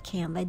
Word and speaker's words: Canva: It's spelled C A N V A, that's Canva.